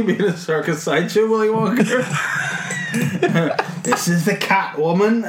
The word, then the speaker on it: Wonka: With Willy Wonka in it. – Yeah. – And stop pronouncing the R in Wonka.